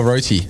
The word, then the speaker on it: roti: Rossi or roti? – Yeah, it's roti.